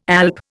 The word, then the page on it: alp: Indian